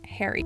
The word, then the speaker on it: charis: The word translated as grace in the New Testament comes from the Greek word charis, which is actually pronounced hairy, which means favor or blessing or goodness.